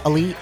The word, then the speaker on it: Ali: Aman Ali on Facebook and Twitter. – How do you spell it? – Aman. Last name is Ali, A-L-I.